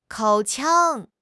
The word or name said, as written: 口腔